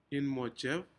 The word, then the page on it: immojen: Aneityum